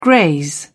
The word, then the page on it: graze: British English